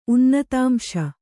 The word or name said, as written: ಉನ್ನತಾಂಶ